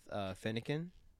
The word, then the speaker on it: Fennekin: Other than Pokemon Go, I would probably if I based on Pokemon Go, I'd probably go with Fennekin. Is that how you pronounce it? – Yeah, yeah.